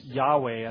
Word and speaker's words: Yahweh: Now, Yahweh is a little bit of a difficult one to define because we don't have all of the information on it, on the correct pronunciation, but the scholars do tend to lean towards Yahweh as the correct pronunciation.